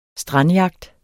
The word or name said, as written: strandjagt